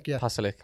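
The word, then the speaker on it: Pašalić: McTominay should obviously slid in and try and get the ball when it was passed to Pasalic. Is that how you pronounce his name? – Pasalic.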